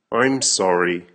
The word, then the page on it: I'm sorry: American English